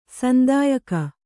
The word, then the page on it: ಸಂತಯಿಕೆ: santayike